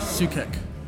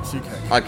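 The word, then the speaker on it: Souček: I believe his last name is pronounced Sukek. – Sukek? – Yeah, Sukek.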